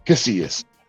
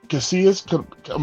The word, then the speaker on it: Casillas: And here he is, Javier Casillas. How are you, bro? – I'm good. – I said it right. Casillas, make sure.